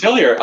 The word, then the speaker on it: Hillier: It's Hillier. I always, if I'm at the store and just I got a prescription refilled recently, it's a classic example. It's always Hillier. That's how they pronounce it.